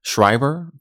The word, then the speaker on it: Schreiber: So in this case, we do have the letter I coming after the letter E, which is why I chose to pronounce it Schreiber.